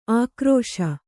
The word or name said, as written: ಆಕ್ರೋಶ